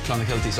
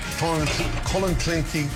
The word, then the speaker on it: Clonakilty: Irish tea bags or Clonakilty sausages from Cork. – Clonakilty. – Clonakilty.